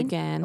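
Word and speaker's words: Ghan: Oh, the GAN.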